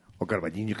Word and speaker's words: O Carballiño: O Carballiño, que bien lo pronuncias.